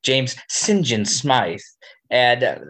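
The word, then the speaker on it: St John: that apparently the way you pronounce this name in England would be Sinjin. – Yes, I did know that. – It's Sinjin.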